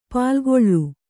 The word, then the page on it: ಪಾಲ್ಗೊಳ್: pālgoḷ